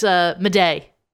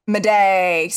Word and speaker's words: Madej: And then there's his cohort, Shane Madage, I think his last name is pronounced. – I don't know. Oh, no, it's Madej. – Madej.